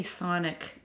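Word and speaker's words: chthonic: And when we say Thonic, that word is spelled C-T-H-O-N-I-C, and it relates to figures that are associated with the earth and or the underworld.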